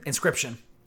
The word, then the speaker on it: Inscryption: Inscription. Inscription spelled like inscription, like a scribe would do, but with a Y in the middle instead, so that the middle of the word says crypt.